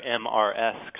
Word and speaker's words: Mrs.: Well, these titles have really changed over time, and in fact, the term mistress, which is actually where MRS comes from originally. MRS was originally an abbreviation of mistress, but that became pronounced as Mrs. even though it retained the R in the abbreviation.